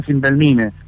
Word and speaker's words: Cimbelmine: La banda se dice y siempre fue Cimbelmine, pero nos empezaron a decir Cimbelmine porque todos piensan que está en inglés.